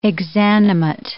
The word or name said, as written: exanimate